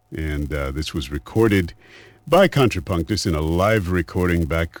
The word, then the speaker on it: Kontrapunktus: Go to Contrapunktus, which is spelled KONTRAPUNKTUS, Contrapunktus.